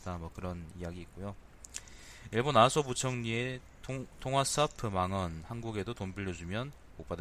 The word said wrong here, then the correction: 통화 스와프: 통화 스와프가든요 내가 이게 발음을 통화 스와프라 그래야 됩니다. 통화 스와프.